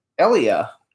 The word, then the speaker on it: Elia: Eli, not Elliot. Eli. – Elia. – It's Elia because how do you say his you name? Say Elliot. – actually, Say, actually, you say Elia. Elia. – Okay, Elia Kazan. – You hit the E.